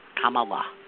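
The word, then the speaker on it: Kamala: But once you get it, it's comma. Like the punctuation mark and la, comma la. That's how you do it.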